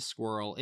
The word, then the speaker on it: Sqirl: I was in LA and I had never been to Squirrel. It's spelled S-Q-I-R-L because everything's cutesy these days.